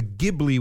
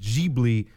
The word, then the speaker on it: Ghibli: Also the name of an Italian aircraft, also called that was called the Ghibli with a hard G. The studio is Ghibli with a soft G.